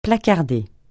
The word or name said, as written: placarder